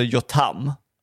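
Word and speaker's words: Yotam: Alltså jag är ju besatt av Ottolenghi, Jotam Ottolenghi. Som jag nu fått lära mig för att han har en YouTube-kanal, att han heter Jotam. Jag har uttalat hans namn fel i alla år.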